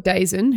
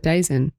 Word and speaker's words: DAZN: In a bit more football news, so there's a platform called DAZN, who we know is quite prominent in football.